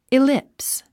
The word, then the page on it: ellipse: ilíps; イリップス